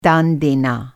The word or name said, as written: दान